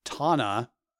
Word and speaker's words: Tanna: So on the island of Tanna, I believe I'm pronouncing that right, T A N N A, which is part of Vanuatu.